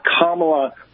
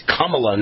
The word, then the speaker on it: Kamala: So he'll actually be able to provide solutions where Kamala will just be complaining. – It's funny because I've always called her Kamala Harris. I get the tense wrong. It's Kamala, not Kamala. But until she got the presidential nomination, I've never had anyone correct me in my entire life.